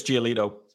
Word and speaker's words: Giolito: Lucas Giolito.